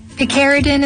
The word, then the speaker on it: Picaridin: We're gonna get the official pronunciation here on Picardigan. Picardigan. – Picaridin is modeled after the piperine molecule. – You didn't even have it said it wrong.